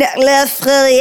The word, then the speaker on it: LeFerrier: laughs> I only just learned to pronounce my last name like 7 years ago, maybe, because I'm from Boston, so it was always Kevin LeFerrier.